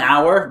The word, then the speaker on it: hour: And an hour, we do not pronounce the H, right?